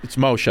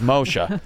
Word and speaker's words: Moshe: and we both love and respect Moshe Kasher. – Uh-huh. – Paul sends me a text once. He goes, you ever seen this kid Moses Kasher? It's Moshe.